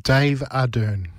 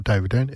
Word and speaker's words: Arden: You got to say it because it's spelled Dave. D-A-V-E-A-D-R-N. E-R-N. And then you say David Dern, but it's not David Dern, it's Dave Arden.